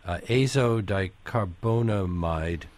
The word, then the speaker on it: azodicarbonamide: Well, but let's look at a few of the particularly interesting additives that are in the book, like azodicarbonamide.